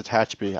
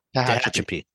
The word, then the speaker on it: Tehachapi: That road from Bakersfield up to Tatchby. I grew up in Tatchby, by the way. From Bakersfield to Tatchby, it's, you know, you're battling. – How do you say the name of this town? – Tehachapi.